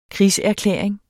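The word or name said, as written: krigserklæring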